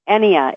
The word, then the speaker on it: Ennea: Ennea. Ennea. It's a Greek, from the Greek word, and it means nine.